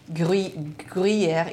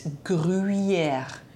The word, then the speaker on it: Gruyère: Because there's a U in front of the Y. So it's not Gruyère. It's Gruyère. Gruyère.